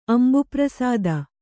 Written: ಅಂಬುಪ್ರಸಾದ

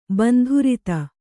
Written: ಬಂಧುರತೆ

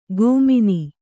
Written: ಗೋಮಿನಿ

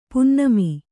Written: ಪುನ್ನಮಿ

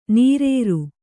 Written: ನಿರಾರ

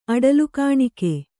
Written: ಅಡಲುಕಾಣಿಕೆ

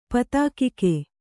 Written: ಪತಾಕಿಕೆ

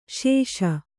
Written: ಸಾಸ

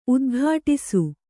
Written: ಉದ್ಘಟಿಸು

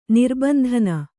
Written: ನಿರ್ಬಂಧನ

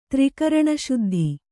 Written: ತ್ರಿಕರಣಶುದ್ಧ

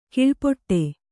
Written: ಕೀಳ್ಪಡು

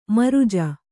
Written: ಮೃಜೆ

